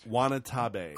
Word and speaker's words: Watanabe: Ken Watanabe is set to, did I say that right? – Watanabe. – Watanabe.